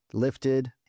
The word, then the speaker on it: Liftid: My name is spelled LIFTID, Lifted, and my last album is Sent, SENT.